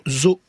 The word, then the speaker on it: zoo: Hugo nous demande, doit-on prononcer les deux O du mot zoo? Daniel Lacote. – Oui, absolument, zoo.